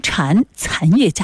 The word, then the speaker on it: 禅蚕业: 左边是一字旁，右边是个简单的单，来禅蚕业家庭农场，这个发音还挺考究的，前面是卷舌音啊，后面蚕业平舌音。